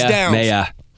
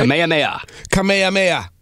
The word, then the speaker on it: Kamehameha: Kamehameha. Come on, man. – How do you say it? – Kamehameha.